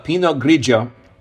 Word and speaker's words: Pinot Grigio: and I think we're now actually pronouncing that correctly. We were schooled on that. Pinot Grigio, whose primarily region to produce it is definitely the nation of Italy.